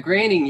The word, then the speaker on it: Groening: Matt Groening, yeah.